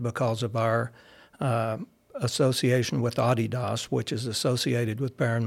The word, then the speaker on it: Adidas: So we have, obviously, being an Adidas school, by the way, that's the correct pronunciation. – Not Adidas.